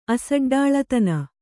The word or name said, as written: ಅಸಡ್ಡಾಳತನ